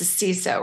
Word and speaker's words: CISO: Not to be confused with CISO, because I was just schooled in that pronunciation. There are no sissies. It's the CISO, right? – That's right.